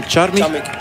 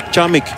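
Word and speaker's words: Charmig: Ik ga eventjes, want ik heb dat al langer willen doen, vragen wat de juiste uitspraak is van die Deen, Charmich. What is the right pronunciation of from Anton Charmich? – Charmich. – Charmich. Oké, okay, thank you very much.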